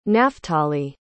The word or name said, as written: naftalie